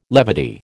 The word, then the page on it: levity: American English